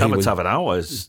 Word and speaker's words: Tavatavanawai: And I don't even know how to say his name, Tomochi Tavatavanawai. I thought Tavatavanawai.